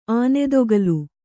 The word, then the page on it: ಆನೆದೊಗಲು: ānedogalu